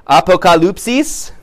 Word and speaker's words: apocalypsis: Apocalypse, which is, I wrote down the apocalypsis, is that how you're supposed to pronounce it? I phonetically wrote it out. Yeah, don't. Apocalypsis is the Greek word there.